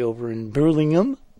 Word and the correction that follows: Birmingham: Never heard it pronounced that way before. – Oh, okay. – Birmingham and things like that, but usually just Birmingham.